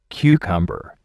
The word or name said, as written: cucumber